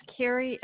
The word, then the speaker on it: Carrie: This is Carrie L. Zynga, and it's spelled C-A-R-R-I-E, and L. Zynga is E-L-Z-I-N-G-A.